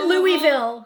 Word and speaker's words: Louisville: I haven't been to, I mean, I've been to you guys always call it Louisville. – You can say it's okay if someone who's not. – I call it Louisville. – Yeah, that's acceptable. Yeah. Just not, pronouncing the S is what's not acceptable.